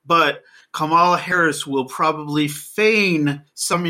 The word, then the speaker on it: Kamala: It's Kamala. In South Asian names tend to put the emphasis on the first syllable. So Kamala, yeah.